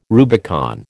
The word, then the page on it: Rubicon: American English